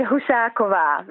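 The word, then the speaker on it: Husáková: Now, I know that a lot of Australians call it Husakoua, but how do you say it? – You say it Husakoua, which is the Czech pronunciation.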